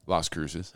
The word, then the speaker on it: Las Cruces: Hey, what, what's the right way to say it? – Las Cruces.